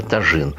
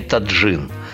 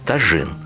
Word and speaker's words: тажин: Сначала учимся произносить слово тажин. Ни в коем случае не таджин – как говорят у нас почти все, с кем я беседовал на эту тему, тажин.